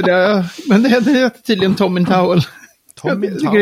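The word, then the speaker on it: Tomintoul: att det där, det där eh, destillerinamnet, då ska man tänka Tom i handduk. Så det heter Tom in Towel.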